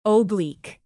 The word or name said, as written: oblique